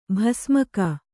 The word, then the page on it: ಬಸಿಮೂಗ: kannada